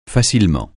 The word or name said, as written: facilement